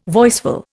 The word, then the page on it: voiceful: Indian